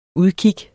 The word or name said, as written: udkig